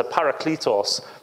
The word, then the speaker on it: parakletos: The paramedic's going to come and help, and then that kind of, that kletos bit, so it's a parakletos, is how it's pronounced in the Greek.